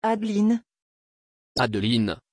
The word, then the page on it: adeline: French